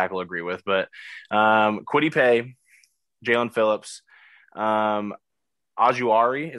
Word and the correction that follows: Ojulari: is that how you pronounce his last name? Ojulari, I think. – Ojulari, yeah. Hey, that was closer than I thought I would be.